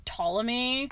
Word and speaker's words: Ptolemy: Ptolemy.